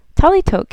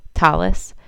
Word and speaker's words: talit: I'm using talit and talitot, the Sephardic and Hebrew pronunciation of these words, but you will hear them being called by their Ashkenazi pronunciations, talis and talisim, by many of the people I interview.